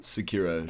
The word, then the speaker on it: Sekiro: Sekiro, oh, Shadows, yeah.